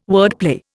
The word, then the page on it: wordplay: Indian